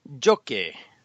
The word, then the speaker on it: Jocke: We are speaking with Jocke. See, I said it right.